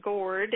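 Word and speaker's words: Gard: Guard actually should be pronounced Gord. It is means farm in many of the Scandinavian languages.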